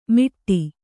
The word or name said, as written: ಮಿಟ್ಟಿ